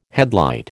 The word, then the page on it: headlight: American English